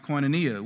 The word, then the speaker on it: koinonia: Now, this word fellowship in the Greek is pronounced koinonia, which means common ownership.